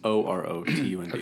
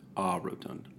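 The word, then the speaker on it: orotund: It's O-R-O-T-U-N-D. – Okay, well, never mind. – But it's O-Rotund. O-Rotund. It says to pronounce it A-Rotund.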